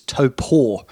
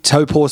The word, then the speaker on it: Taupo: He still does a bit of racing, but the circuit that I'm talking about most notably in New Zealand is Taupo, or as we've recently come to know that we've been pronouncing it wrong all this time, it's Taupo.